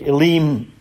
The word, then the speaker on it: Elim: "And then they came to Elim," or Elim. I think Elim is the more accurate Hebrew pronunciation.